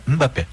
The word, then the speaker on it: Mbappé: Então, quando tem o M ou N no final, é né? Então é Mbappé. As pessoas falam Mbappé, Mbappé. – Eu falava Mbappé. – O Mbappé, Mbappé. – Mbappé.